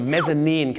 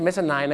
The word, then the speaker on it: mezzanine: the mezzanine ครับผมนั่นแหละฮะ M E double Z A N I N E ไม่ใช่ mezzanine นะครับ mezzanine เหตุผลที่เขาต้องออกเสียงว่า nine เนี่ยเพราะถ้าไปบอก mezzanine เดี๋ยวมันนึกว่าเป็นชั้น 9 เดี๋ยวจะทับกันได้